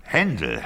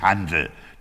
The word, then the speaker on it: Händel: Händel, Herr Pach, mit einem Ä. Händel. – Aber auf Ihrer Einladung stand doch... – Ach, meine guten Engländer, die schreiben mich Handel, damit sie mich Händel aussprechen können.